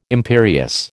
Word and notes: imperious: American English

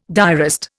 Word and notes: diarist: Indian

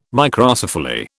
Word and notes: Microcephaly: British English

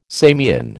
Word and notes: Samian: American English

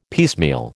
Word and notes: piecemeal: American English

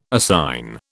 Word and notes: assign: British English

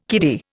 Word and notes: kiddy: Indian

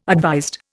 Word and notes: advised: Indian

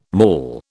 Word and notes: maul: British English

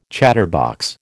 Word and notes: chatterbox: American English

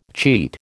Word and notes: cheat: American English

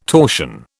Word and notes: torsion: British English

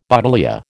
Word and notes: buddleia: American English

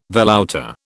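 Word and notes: velouté: British English

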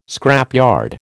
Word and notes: scrapyard: American English

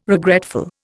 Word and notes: regretful: Indian

